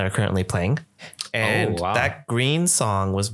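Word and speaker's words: GReeeeN: It's a song by this band called Green with like four E's. So it's Green. You gotta say the long E.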